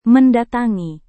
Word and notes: mendatangi: Indonesian

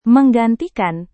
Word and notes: menggantikan: Indonesian

